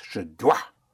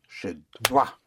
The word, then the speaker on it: je dois: I must, I must, I must is je dois. Je dois, je dois, I must, je dois. – Je dois. – Je dois, I must. Je dois. I must. Dois spelled D-O-I-S.